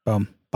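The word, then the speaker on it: Boehm: Charles, and I might pronounce his last name incorrectly, Bohem? – Boom. Bomb. – Boom. – Bomb. Boom. – Boom. – That guy. – Charlie Boom.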